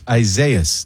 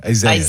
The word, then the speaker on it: Isaias: Isaias. Just say Isaiah with an S at the end. Isaias. – Isaias. – Isaias.